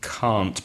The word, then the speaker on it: can't: It's difficult to hear when it's the positive can and the negative can't, because native speakers don't pronounce the t at the end of can't, which is cannot. So what I suggest is that students focus on the vowel sound. Now, in connected speech, can becomes can, like I can speak English, I can swim. And the negative can't, the vowel sound is longer, it's R, like car or far.